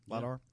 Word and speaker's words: LiDAR: LiDAR, yeah.